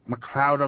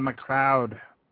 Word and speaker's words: MacLeod: and I always love to say it like the movie Highlander, MacLeod of the Clan MacLeod, but he does a site where he does some music, and he has it royalty-free for people to use.